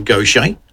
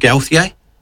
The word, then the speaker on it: Gauthier: Her name is Mary Gauthier. That's apparently how you pronounce it. I would have said Gauthier, but this is our Lady of the Shooting Stars.